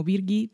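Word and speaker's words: Birgitte: La serie está protagonizada indiscutiblemente por Sitze Babet Nussen, que se escribe el nombre del personaje Brigitte, pero lo pronuncian como Birgit. Birgit.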